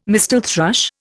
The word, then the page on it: mistle thrush: Indian